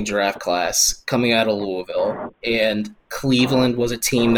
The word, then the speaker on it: Louisville: Well, I'm pretty sure you said Louisville, so that's the way to my heart is pronouncing it correctly.